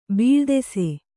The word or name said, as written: ಬೀಳ್ದೆಸೆ